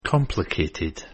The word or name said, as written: complicated